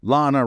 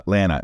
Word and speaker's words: Lana: Lana rhymes with banana, Lana, it's Lana rhymes with banana, W, Nancy D, Brian G, Sarah W, Artoon K, Justin T, and Lucy and Jeremy G.